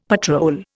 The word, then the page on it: patrol: Indian